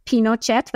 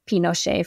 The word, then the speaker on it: Pinochet: And I had never heard of Pinochet. That's how they say it in Spanish. You might have heard Pinochet for listeners. I say Pinochet because that's how they say it there.